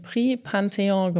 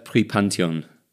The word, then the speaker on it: Prix Pantheon: und hat auch schon den Prix Pantheon gewonnen. Und ich wusste, dass ich es falsch ausspreche. Sag du jetzt nochmal, was du gewonnen hast. – Ähm, ich glaube, das heißt Prix Pantheon. Pantheon, ja. genau, man redet schnell.